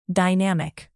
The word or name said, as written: dynamic